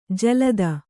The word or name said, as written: ಜಲಧಿ